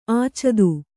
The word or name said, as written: ಆಚದು